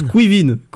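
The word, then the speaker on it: Caoimhín: Kayomin. – Alors, ça se prononce Quivin. – Quivin.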